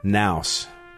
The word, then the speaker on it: Knauss: Army Staff Sergeant Ryan Knauss. I think it's a silent K, K N A U S S.